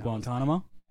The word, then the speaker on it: Guantanamo: In Guantanamo? – Hell yeah.